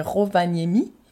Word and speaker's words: Rovaniemi: Donc parce qu'apparemment il y a cette petite ville euh, alors je sens que je vais mal le prononcer, mais c'est Rovaniemi.